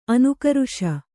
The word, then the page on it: ಅನುಕರಿಸು: Kannada